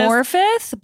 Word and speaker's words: Morfydd: Morfyd, but it's spelled M-O-R-F-Y-D-D. So that's Morfyd, why we don't know what's going on. – I also was looking at the phonetic pronunciation and still couldn't do it. – Morfyd. I would Morfyd. Never guess that that was a T-H with the two D's.